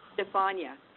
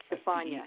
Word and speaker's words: Stefania: How do you pronounce his mother's name? – Stefania, S-T-E-P-H-A-N-I-A. Stefania. – S-T-E-F-A-N-I-A. – It's how'd you say that again, Richard? – S-T-E-F-A-N-I-A, Stefania.